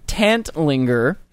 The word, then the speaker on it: Tantlinger: Tantlinger.